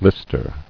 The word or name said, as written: Lister